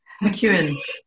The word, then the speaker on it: McKeown: McKeown.